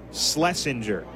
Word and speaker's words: Schlesinger: It is spelled S-C-H-L-E-S-I-N-G-E-R. Schlesinger.